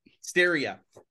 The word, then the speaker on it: Stariha: Am I saying it right? You got it. Stariha.